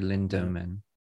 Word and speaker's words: Lindemann: Oh, yeah, I'm putting an R in it. Yeah. – You are so, putting an R. Lindemann. – Yeah, no, it's Lindemann. Or do you not even pronounce the E? Would it be Lindemann? – Lindemann, no. It'd be Lindemann.